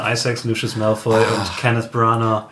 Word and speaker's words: Branagh: Naja, da ist doch ein GH am Ende des Namens. – Ja, das wird Branagh. – Das ist stumm.